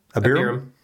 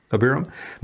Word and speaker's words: Abiram: Abiram. – Abiram. – Abiram.